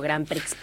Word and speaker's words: Grand Prix: Que tenía, pero no, era la que le seguía hacia abajo a la de Grand Prix, que conocemos como Grand Prix, pero la X no se pronuncia en francés.